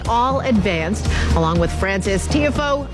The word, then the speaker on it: Tiafoe: Tiafoe.